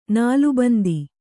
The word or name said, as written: ನಾಲಬಂದ